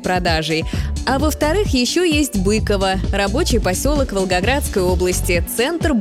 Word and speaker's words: Быково: Во-первых, это старинный, ныне недействующий аэропорт Быково в Московской области. Да, правильное ударение здесь именно на второй слог, и никак иначе.